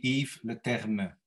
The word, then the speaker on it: Yves Leterme: Well, it's a French name, actually, although I speak Dutch. I mean, the correct pronunciation would be Yves Leterme.